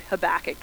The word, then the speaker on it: Habakkuk: But other of us say the name Habakkuk. Habakkuk, that's the way to pronounce his name.